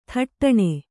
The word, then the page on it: ಥಟ್ಟನೆ: thaṭṭane